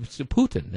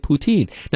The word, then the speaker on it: poutine: The E is silent, and it's like Putin, kind of like the Russian dictator, but, you know, but Putin, that's the E is silent. And I thought somebody was pulling my leg, but, I mean, that's all these different websites say you Americans are pronouncing Putin wrong by calling it poutine.